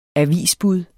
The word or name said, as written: avisbud